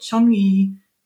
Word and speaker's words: Changi: Changi Singapore Airport. So I don't know. It's spelled C-H-A-N-G-I.